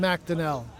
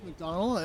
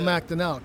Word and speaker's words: McDonnell: You can leave that in because everybody says McDonald, and then, like, it's nice to have them address it like that. No, it's McDonnell. But it doesn't really... Like, it's something that I've kind of, like, stopped fighting against. – Oh, really? – But, I haven't changed my mind and start saying my name the way other people say it. It's still McDonnell.